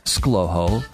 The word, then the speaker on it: ScLoHo: It's pronounced ScLoHo, and it's spelled S-C-L-O-H-O.